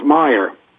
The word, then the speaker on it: Meyer: It's Meyer. – It's Meyer. You had the M-E-Y, so I wasn't sure.